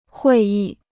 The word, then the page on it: 会议: huì yì